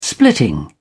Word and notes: splitting: British English

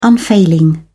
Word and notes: unfailing: British English

